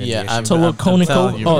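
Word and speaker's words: Tolokonnikova: Tolokonikovia. – I'm just kidding. Kovia? There's no I before the A.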